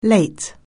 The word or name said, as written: late